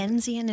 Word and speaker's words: Dickensian: Kristen, did I even say that right? Dickensian existence? – Dickensian is correct.